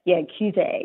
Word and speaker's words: Cuvée: Did I say that right? Cuvée. – Yeah. Cuvée. Yeah.